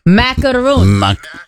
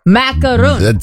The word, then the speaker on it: macaron: He just sent a video so I can say the pronunciation of that French pastry right. – Macaron. – Macaron.